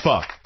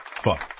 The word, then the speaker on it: pho: It's pho. Pho. Pho. – Pho. – It's pho. – It's pho. – It's pronounced pho.